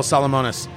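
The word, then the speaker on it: Salomones: This is the Royal Salamonis. That's how I was told to pronounce it.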